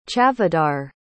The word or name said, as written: chavdar